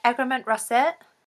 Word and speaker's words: Egremont Russet: It's their Egremont Russet. Which is, did I pronounce that right? Is it? – Yeah, absolutely.